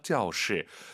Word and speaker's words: 教室: 아하, 사성입니다. 教室. 이게 보통 이제 A가 B에게 뭘 가르치다라고 할 때는 이제 일성을 생각하시면 되고요.